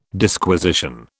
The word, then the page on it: Disquisition: British English